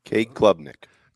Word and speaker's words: Klubnik: Klubnik Cade Klubnik Klubnik.